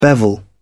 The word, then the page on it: bevel: British English